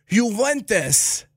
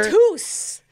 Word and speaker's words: Juventus: So, your chance to win a family four-pack to see the inaugural Soccer Champions Tour when Italy's Juventus, excuse me on the mispronunciation, Italy, thank you for your contribution to not only soccer. – Juventus.